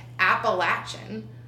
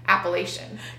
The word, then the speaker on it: Appalachian: No, listen, though, there is this whole thing how if you're born in the South, you pronounce it Appalachian, and then if you're born in the North, you pronounce it Appalachian.